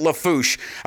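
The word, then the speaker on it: Lafourche: We were pronouncing the town of, we were pronouncing it Houma, H-O-U-M-A. He said it's Houma. And then LaForche, I'm guessing that's something, a town or some parish or some shit they have down there. He said they pronounce it LaForche.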